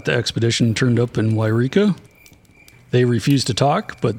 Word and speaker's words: Yreka: Yreka, I believe is how it's actually pronounced, for which Mount Shasta is named.